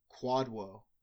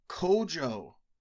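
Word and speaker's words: Kwadwo: It's spelled Q-W-A-D-W-O. We pronounce it Quadwo. But apparently, we were wrong. He wrote in saying, it's time to pronounce my names well, lads. It's Kojo.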